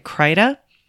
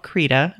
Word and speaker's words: Krita: Now, I always pronounced it Krita, but I recently found that it's supposed to be called Krita. It's K-R-I-T-A.